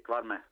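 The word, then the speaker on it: Kvarme: Que, fue jugador de la Real, aquel central cuyo nombre pronunciaré fatal, Cuarme, Que fue eh, subcampeón de liga con la Real de, de Sí, sí, Cuarme. Perfecto.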